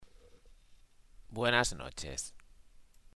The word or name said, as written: buenas noches